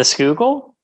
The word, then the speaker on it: Schuylkill: How do you pronounce the name of the river that flows through downtown or right next to it? – The Schuylkill? – Yeah, that makes no fucking sense.